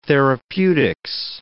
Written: therapeutics

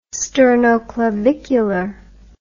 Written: sternoclavicular

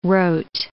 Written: rote

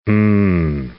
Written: um

